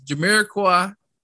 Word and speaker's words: Jamiroquai: It's Jamiroquai.